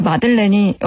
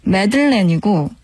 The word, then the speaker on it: 마들렌: 이게 보니까 그 마들렌이 여러분 아시죠? 조개 모양으로 생긴 작은 카스테라 종류의 빵인데 되게 달콤하고 맛있잖아요. 근데 이 발음이 미국 영어로는 매들렌이고 영국 영어로는 마들렌이라고 하네요. 우리는 보통 마들렌이라고 부르죠.